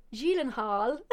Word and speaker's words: Gyllenhaal: Jake Gyllenhaal, because that's actually Swedish. – Don't tell me it's Gyllenhaal. – No, because it's actually Swedish. Apparently there's only one person who isn't Swedish that's pronounced it right, and that was Conan O'Brien when he was on the Conan O'Brien show that I watched. It's actually Gyllenhaal.